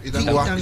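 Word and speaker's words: Itauguá: Así se pronuncia, ¿verdad? ¿Dije bien, Itanguá, niño? – Sí, Itanguá.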